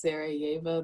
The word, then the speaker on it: Sarajevo: My next set of questions is, how long you were in, and I'm so sorry if I butchered this, I think it's Sarajevo, that's the city.